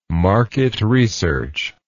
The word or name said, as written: market research